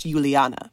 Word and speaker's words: Juliane: And her name is spelled a bit like Julianne. That's what we would say in English, but she's German. So that's why it's pronounced Juliana.